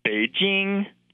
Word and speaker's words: Beijing: That correct pronunciation is Beijing. And I think it's quite simple to get it pretty close. And I feel like the jing, as in jingle bells, is something that all English speakers should be able to manage.